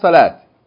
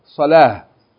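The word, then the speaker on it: Salah (صلاة): It's not salat. Salah.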